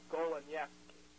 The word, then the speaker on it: Goleniewski: There was once a guy named Michael Golenyevsky. That name is spelled G-O-L-E-N-I-E-W-S-K-I.